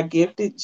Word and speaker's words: Gift3D: Again, we are gifted. G-I-F-T-3-D, pronounced gifted.